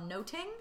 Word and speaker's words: nothing: Um, which is so we talked about how it would have been essentially pronounced "much ado about noting" mm-hmm.